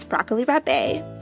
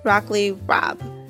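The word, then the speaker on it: broccoli rabe: I was the wealthy aunt, Miss Broccoli Rabé, but it's actually pronounced Broccoli Rob, like rob a bank.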